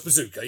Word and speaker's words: Bezucha: Yeah, his surname is spelled B-E-Z-U-C-H-A, but it's pronounced Bezucha.